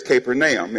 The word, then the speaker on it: Capernaum: Now, in America, we say it's Capernaum. The correct way of pronouncing it really is Capernaum. It is the village of Nam. But since we're in America, it's Capernaum.